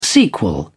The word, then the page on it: sequel: British English